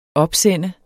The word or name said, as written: opsendte